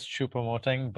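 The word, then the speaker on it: Choupo-Moting: It is Choupo Moting, but that was like one for four.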